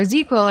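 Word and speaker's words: ZzzQuil: or Zedquil, or ZQuil, as Americans say.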